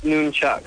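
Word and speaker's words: nunchaku: Yeah, it's pronounced Noonchuck. – Oh, God, what am I doing? – Not N-U-N-Chuck.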